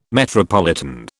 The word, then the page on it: metropolitan: British English